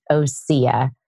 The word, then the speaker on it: Osea: It's pronounced Osea. Osea